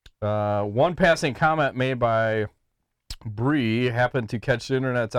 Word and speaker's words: Brye: It says, my MMA News conducted an interview with a pro WMMA fighter named, how would you pronounce B-R-Y-E? – Bree. – Bree? Okay. I was going to go with Bri, but that would be kind of weird.